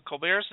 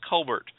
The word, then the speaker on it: Colbert: Originally, his surname was pronounced Colbert. Stephen Colbert's father, James, wanted to pronounce the name Colbert, but maintained the Colbert pronunciation out of respect for his own father. He offered his children the option to pronounce the name whichever way they preferred.